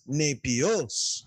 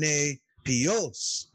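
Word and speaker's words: nepios (νήπιος): I don't know that I did that really well when I was reading, but how it should sound is nepios, nepios, right? So you get the stress on the eta and the stress on the omicron, nepios.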